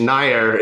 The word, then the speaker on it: Nair: Almost there. Roopesh Nair is how you say Nair.